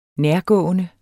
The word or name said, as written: nærgående